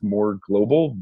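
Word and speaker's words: Mörglbl: His band for a long time, I believe, is pronounced more global, but it's M with an umlaut, O-R, and then it's G-L-B-L.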